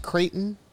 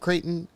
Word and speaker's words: Creighton: No, my name is Creighton. So it's Pope Creighton. It's spelled C-R-E-I-G-H-T-O-N. Just like the University of Nebraska.